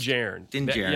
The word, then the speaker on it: Din Djarin: Din Djarin, I think it's pronounced. – Din Djarin.